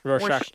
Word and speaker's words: Rorschach: Rorschach, yeah.